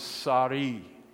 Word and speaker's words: Sarai: Now, I've been asked many times how you pronounce S-A-R-A-I, because her name's going to be changed to Sarah here pretty soon. And I really think the way that this ought to be pronounced is Sarai. Sarai.